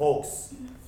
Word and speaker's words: Folks: Folks. Folks って書いてありますが、L は発音しないと考えてください。